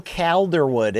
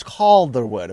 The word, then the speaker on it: Calderwood: Oh, the, the pronunciation police have hit me up in the DMs and say, uh, stop calling it JoJo Calderwood. It's it's it's Calderwood apparently, or Cal- Calderwood.